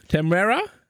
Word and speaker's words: Temuera: Im Trailer war bereits zu sehen, wie Boba Fett, gespielt von Temuera. Ja, ist jetzt, das ist ein Trailerschnack-Problem. Keiner von uns kann Namen aussprechen. Temuera Morrison? Temuera? Ja, genau so den Platz des Verbrechers Jabba the Hutt einnimmt.